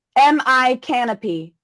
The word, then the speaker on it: Micanopy: Now out there, there's a place called, it's spelled Micanopy, M-I canopy. – Okay. – It's pronounced Micanopy.